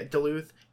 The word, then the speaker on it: Duluth: Did I pronounce that right? – Duluth, yeah. – Yeah, Duluth.